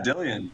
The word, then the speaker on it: Dillion: Yeah, Dillion. You got it. Like Million Dillion.